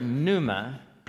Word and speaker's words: pneuma: It's the word pneuma, pneuma, and the silent P right there, pneuma.